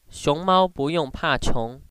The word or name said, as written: iong